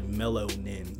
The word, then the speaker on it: Mellownin: you can follow me at Melanin, but it's spelled MELLOWNIN. It's like Melonin.